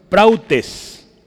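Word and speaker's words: prautes (πραΰτης): le escribo en griego arriba y abajo es prautes. Así se pronuncia.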